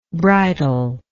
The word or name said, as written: bridler